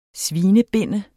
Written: svinebinde

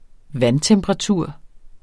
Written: vandtemperatur

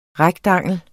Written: REKTANGLERNES

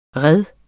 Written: REDENES

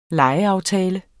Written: lejeaftale

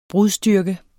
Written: brudstyrke

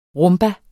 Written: rumba

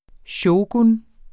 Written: shogun

